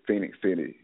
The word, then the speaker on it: Phenix City: Is it Phoenix or Phoenix? How do you pronounce that? – It's Phoenix. It's Phoenix City. – Phoenix. – It's Phoenix City. Okay. It's like Phoenix, but with an E.